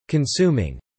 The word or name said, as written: consuming